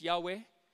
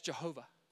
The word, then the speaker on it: Yahweh: But we pronounce it in English either normally as Yahweh or as Jehovah.